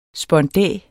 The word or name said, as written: spondæ